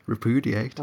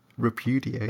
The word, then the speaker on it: repudiate: Sorry, what was that? – Repudiate. – Oh my god, say it properly. – Repudiate. – There we go.